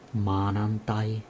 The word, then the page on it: maanantai: [ˈmɑːnɑn̪t̪ɑi]; /ˈmɑː.nɑn.tɑi/